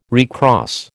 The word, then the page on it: recross: American English